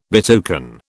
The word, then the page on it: betoken: British English